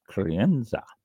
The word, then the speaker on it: Crianza: This is one of Spain's quality classifications, Crianza.